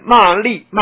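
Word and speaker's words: 詈: 若一切众生骂，这个字应该念作吃啊，利啊，哪个利？乐意利啊啊！若一切众生骂利，骂利是言语上的伤害，刀杖是直接拿这个器物哈、啊，伤害加害于身，加害于身，种种这样的伤害啊，菩萨摩诃萨于此中。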